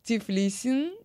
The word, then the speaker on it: Тифлиси: Я думаю, что именно в этом ресторане, который называется Тифлисин, да, красиво, Тифлисин. А знаете, куда правильное ударение ставится? Правильно, конечно же, Тифлиси. Это как Тбилиси, только Тифлиси.